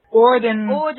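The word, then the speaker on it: Auden: I mean, W.H. Holden, am I saying it correctly? Orden, I think it is.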